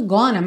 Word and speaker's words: gonna: Aí você está lendo going to e está ouvindo gonna, mas isso aqui se pronuncia desse jeito? Não exatamente, é uma mudança que eles costumam fazer, mas a pronúncia do G-O-I-N-G seria going mesmo, mas na hora que junta ali going to, eles naturalmente já vão ler wanna. E want to, wanna.